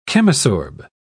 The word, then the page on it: chemisorb: British English